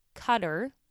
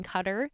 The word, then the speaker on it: Qatar: The tournament will be held in Qatar, spelled Q-A-T-A-R. Jacob just taught me how to pronounce that before the recording of this episode.